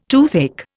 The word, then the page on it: toothache: Indian